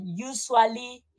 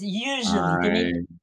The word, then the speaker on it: usually: Eh, me gusta mucho cómo has pronunciado usually. Es un encuentro con mis alumnos que dicen usually y no es así, es usually. – Tiene, que fluir un poquitín de aire entre los dientes, no abrir tanto la boca, usually.